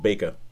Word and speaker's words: Bakker: In my head, I should add, the surname should be pronounced Baker because it's B-A-K-K-E-R. – Oh, no, it's the Dutch. It's the Dutch spelling, and the Dutch actually pronounce it Baker. Theemo de Baker. I mean, I know that's because of the Dutch tennis player Theemo de Baker, and they actually do pronounce B-A-K-K-E-R, Baker.